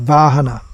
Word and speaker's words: vahana: That actually comes from Sanskrit, vahana. With the v is pronounced as w, and the h is pronounced as g. Still in Tamil, h and g are interchangeable.